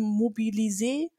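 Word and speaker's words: Mobilizon: Das ist eine Software, das ist französisch ausgesprochen, Mobilisé, wo du halt Events halt erstellen kannst, wo du Leute damit anteasern kannst.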